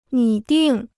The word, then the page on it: 拟定: nǐ dìng